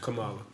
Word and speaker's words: Kamala: Nah, I just think there's always been a lot of stuff about Kamala Harris, you know what I'm saying? And everybody saying that. – Kamala.